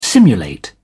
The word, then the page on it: simulate: British English